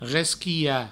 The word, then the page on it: resquilhar: resquiha; Provençal